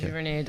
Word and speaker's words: Giverny: And then the next one was, am I pronouncing this right? The Giverny Document? – Yes, Giverny Document, yes.